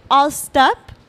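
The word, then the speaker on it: Alst: C'est All Stop. parce que c'est Always Stand Up.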